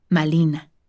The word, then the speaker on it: Malina: Dado que el fonema R no forma parte del inventario de muchas lenguas mesoamericanas como el náhuatl, este fonema del nombre castellano de Malintzin fue pronunciado con un fonema parecido de la lengua meta, L. De este modo, su nombre adaptado al náhuatl se pronunciaba como Malina.